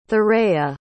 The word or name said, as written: thuraya